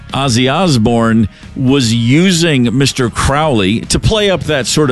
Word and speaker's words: Crowley: Now, Aleister Crowley pronounced his last name Crowley, but in history, we all say Crowley. It just rolls off the tongue a little bit better.